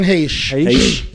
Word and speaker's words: Heche: Anne Heche.